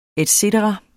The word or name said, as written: et cetera